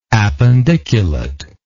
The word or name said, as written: appendiculate